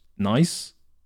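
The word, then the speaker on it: gneiss: That word is actually pronounced nice.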